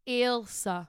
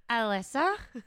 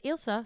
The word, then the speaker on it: Ailsa: The pronunciation of my name is Ailsa. Ailsa. A-I-L-S-A. I'm sick and tired of people saying, Alyssa.